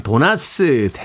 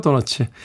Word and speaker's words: 도너츠: 도너츠죠, 도너츠. 도나스는 사실은 음, 그렇죠. 일본식 발음이라 별로 그렇게 장려되는 발음은 아닙니다.